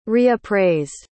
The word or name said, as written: reappraised